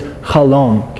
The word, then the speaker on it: chalom: I forgot to mention to you, the Hebrew word for a dream is chalom. Can we all say chalom? Like C-H-A-L-O-M. Chalom. So in the Hebrew word for egg white, I would spell that C-H-A-L-M-U-T.